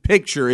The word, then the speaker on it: picture: Knew the C was in there. You just weren't pronouncing. Was I not saying the C? – No, you weren't. You were not. – Same word. Well, picture and pitcher.